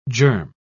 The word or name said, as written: germ